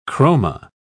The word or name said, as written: chroma